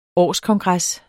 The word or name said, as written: årskongres